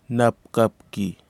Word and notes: napkapki: Kwamera

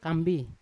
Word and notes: kambi: Yawa

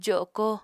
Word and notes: jo7ko: apatani

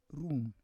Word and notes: rum: Roon

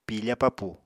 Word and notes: piifa papù: Apatani

